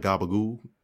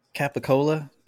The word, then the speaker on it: capicola: Like Gabagoo? Gabagoo. What is that? – Capicola, that they just pronounce wrong for no reason.